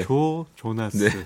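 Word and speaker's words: Joe Jonas: 그 조조장 아, 이렇게 발음이 이게 참 어려운 발음이에요. 여러분 오해하지 마시고요. 네. 아, 네. 네. 조 조나스.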